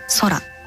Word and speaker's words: 宇宙: 宇宙と書いて空と読むんですけど、